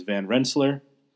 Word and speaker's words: Van Rensselaer: If anyone is once again reading along, you're going to see the name as Van Rensselaer, as a lot of people pronounce it. Trust me, having worked at Saratoga National Historical Park, and this, a lot of the pronunciation I'm giving you is correct. It is Van Rensselaer.